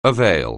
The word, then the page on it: avail: anglais